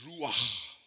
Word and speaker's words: Ruach: You got to go on it. Can't go sh on it. Ruach. It's Ruach. C-H is pronounced. And when you're Afrikaans speaking, it helps.